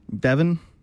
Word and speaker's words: Devon: Devin, I'm pretty okay with it. If I get a nitpick about it, I don't care that it's spelled with an O, but it is spelled with an O, D-E-V-O-N.